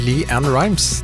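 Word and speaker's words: LeAnn Rimes: Det var Liamara Brown. Det var for tidlig å uttale artistnavnet Lee-Ann Rimes. Det var akkurat som om ketchupflaska plutselig løsna, og det kom altså ut alt på en gang her. Lee-Ann Rimes heter hun.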